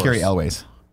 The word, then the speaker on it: Cary Elwes: And some behind the scenes drama with Cary Hughes. Is that how you say his name? Made the franchise worse. – Cary Elwes.